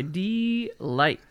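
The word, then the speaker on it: Deee-Lite: And that's how you pronounce that, by the way. – It's yeah, D-Light. It is. That's right. It's D-Light. There's multiple E's in there. There's no doubt.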